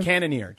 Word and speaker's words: Cannonier: Cannonier.